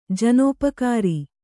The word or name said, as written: ಜನೋಪಕಾರಿ